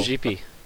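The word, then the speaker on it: Gipi: He introduced me to, now pardon my pronunciation, but that Italian guy, is it Gippy or Jippy? – Jippy, yeah.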